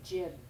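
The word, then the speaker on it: Grzyb: My last name is said Jib, as if it's spelled J-I-B.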